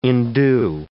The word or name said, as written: endue